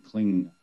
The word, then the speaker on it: klim: There's a nasal m, like ang, like m, like this, through the nasal, in pronunciation, kling.